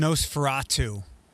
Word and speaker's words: Nosferatu: It's pronounced Nosferatu.